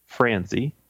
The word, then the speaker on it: Franzi: I am Richard Franzi. Last name is spelled F as in Frank, R-A-N-Z-I.